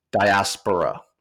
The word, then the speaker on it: diaspora: And where we're landing is diaspora. And we'll put the pronunciation in the show notes, but diaspora. You know, there's other ways. So you said diaspora. I've heard people say diaspora, but we're going with diaspora.